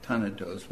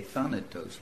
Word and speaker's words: Thanatos: In German it would be Thanatos, but in English we say Thanatos, right?